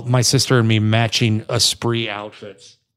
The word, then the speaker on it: Esprit: And that's it's spelled like Esprint. – Yeah, and I always thought that's how you said it. No, it's not. – It's Esprit.